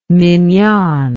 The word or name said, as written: mignonne